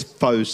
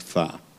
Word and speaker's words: pho: You might think it's faux soup because it's pr- it's spelt P H O, but it's actually correctly oh. pronounced far.